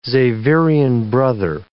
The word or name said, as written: Xaverian Brother